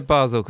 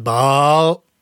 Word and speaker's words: Basel: I'm assuming it's the place in Switzerland, which I don't think is pronounced like that at all. I think it's pronounced Baal, like that.